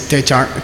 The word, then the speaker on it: techak: In the writing it's tachak, but in the spoken it's techak.